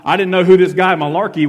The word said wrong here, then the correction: Malachi: was that the preacher was talking about, or Malachi. I, I didn't understand it, and then I realized later on in my development how to pronounce his name properly.